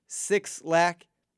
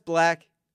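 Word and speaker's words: Blxst: And that's where a guy like Black comes into play, or some of you may know him as Six Lack, Six L-A-C-K. It's pronounced Black. I know he stylizes it differently, but it's not Six Lack, just so you know.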